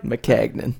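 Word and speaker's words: Maccagnan: Something real weird about their front offense, in which Mike Mackinnon... Am I saying that right? – McCagnon.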